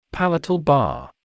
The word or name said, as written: palatal bar